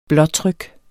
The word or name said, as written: blåtryk